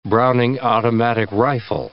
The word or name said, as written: Browning automatic rifle